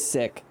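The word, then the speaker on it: Sikh: Isn't it pronounced sick, though? – No. Yeah, it is sick.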